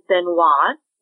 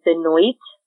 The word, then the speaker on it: Benoit: And we find out her last name is Benoit, which real quick, the audiobooks do pronounce it as Benoit, but according to Marissa Meyer's website, it is Benoit, which is also a French pronunciation, so that's the one I choose to leave.